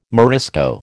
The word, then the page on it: Morisco: American English